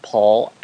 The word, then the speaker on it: Paul: You'll also see right after my name is my Twitter handle, which is at Paul Allen Cliff.